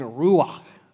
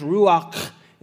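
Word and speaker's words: ruach (רוּחַ): Okay, now that was sort of good, but like that sound at the end, it's ruach. It's like you've got some phlegm in your throat or some like popcorn back there. You're trying to get that kernel out.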